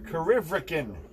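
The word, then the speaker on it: Corryvreckan: Karivrikin is how you pronounce it.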